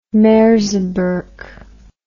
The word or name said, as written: merseburg